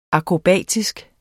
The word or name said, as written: akrobatisk